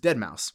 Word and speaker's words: Deadmau5: But it's spelled DEADMAU5, like the number 5. – Mm. – So if you don't know who that is, it looks like Deadmau5.